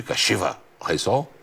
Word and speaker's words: Kashiwa Reysol: Ficou boa essa pronúncia de Kashiwa Reysol? É assim, não, Lamir? Ficou.